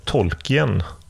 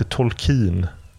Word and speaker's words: Tolkien: Och jag har i hela mitt liv sagt Tolkien. Han heter Tolkien.